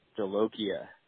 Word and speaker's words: Jolokia: All right, I'm staring at the bottle right now, and it's boot, and then the second order is J-O-L-O-K-I-A. So phonetically, I mean, that looks like Jolokia to me. – He made that. – You were saying boot Jolika, but I think it's Jolokia.